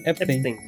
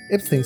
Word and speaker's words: Epstein: Sí, Epstein. – Epstein.